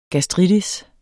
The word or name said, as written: gastritis